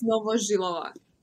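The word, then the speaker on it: Novozhylova: It's Novozhilova.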